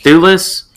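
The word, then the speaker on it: Thewlis: I wanted to mention the actor, David Lewis, or Lulis, or I don't know how to say it. – Thulis, I think. – Thulis. – Oh, yeah.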